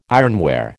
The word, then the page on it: ironware: American English